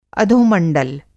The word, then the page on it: अधोमंडल: Hindi